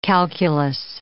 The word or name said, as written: calculous